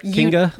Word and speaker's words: Kinga: Okay, Kinga Phillips. Did I say that right? Kinga? – You did. You even got Phillips right.